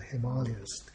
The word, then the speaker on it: Himalayas: The north is the Himalayas or the Himalayas, given the proper pronunciation, and the great mountains covered uh, in their lower slopes with green forests.